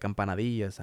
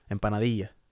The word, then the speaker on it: empanadilla: not how that's not how you say it in Spanish. – Empanadilla. – Empanadilla.